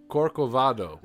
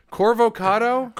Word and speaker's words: Corcovado: This is Corcovado, which I kept mispronouncing because like a very... I was a very like ignorant white man thing to be like. – Man. – I was like, Corvocado? – Corvocado?